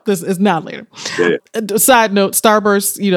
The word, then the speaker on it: Now and Later: Now and Later. – Now, that. Yeah. And notice that I pronounced it as Now and Later, but it's actually Now Later.